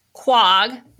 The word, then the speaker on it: Quogue: The reason I went to Quogue, did I say it right? – Yep.